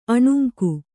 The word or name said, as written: ಅಣುಂಕು